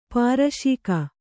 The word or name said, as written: ಪಾರಶಿಕ